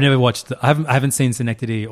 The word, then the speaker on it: Synecdoche: Adaptation, Synecdoche, New York.